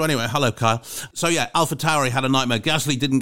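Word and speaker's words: AlphaTauri: I've been told it's Alpha Tauri. I used to say Alpha Chori because I thought the Chori bit stood for Taurus, which you pronounce as Chori.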